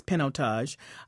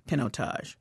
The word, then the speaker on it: Pinotage: Now, I just learned that that is the correct pronunciation, is Pinotage. I've been calling it Pinotage because it looks like Pinot on the front end of it. But in fact, it's Pinotage.